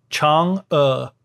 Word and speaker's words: Chang'e: So it's spelled C-H-A-N-G apostrophe E, Chang'e.